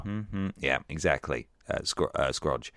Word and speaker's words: Scrooge: You play as the lead character, Scroogey.